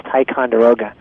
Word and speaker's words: Ticonderoga: Everybody mispronounces the company. It's Ticonderoga.